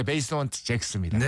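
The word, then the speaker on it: Basement Jaxx: 이 베이스먼트 잭스라고 읽어야 되나요? – 네, 베이스먼트 잭스입니다.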